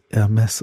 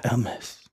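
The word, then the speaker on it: Hermès: Außerdem wird das Hermes ausgesprochen. Das H ist stumm. Ja, Hermes. Hermes ohne H.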